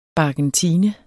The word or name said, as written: BARKENTINE